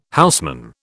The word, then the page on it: houseman: British English